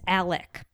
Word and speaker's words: Alex: It's Alec or Alex? – It's Alec, but it's spelled Alex.